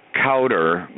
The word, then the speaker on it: Cowder: Cowder.